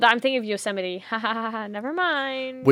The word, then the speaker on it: Yosemite: No, it is Yosemite. It is Yosemite. That is the way that they say it, but it's bullshit. Look at the way it's spelled.